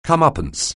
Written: come-uppance

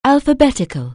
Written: alphabetic(al)

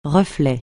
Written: reflet

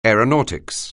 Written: aeronautics

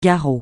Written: garrot

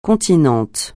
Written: continent

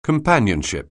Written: companionship

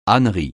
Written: ânerie

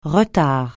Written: retard

